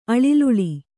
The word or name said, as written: ಅಳಿಲುಳಿ